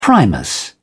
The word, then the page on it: primus stove: British English